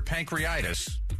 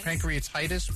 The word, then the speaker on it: pancreatitis: The high fat content of turkey of turkey skin could trigger pancreatitis. Am I saying that right? Pan- yeah. – Pancreatitis. Pancreatitis. It could cause bad things to happen.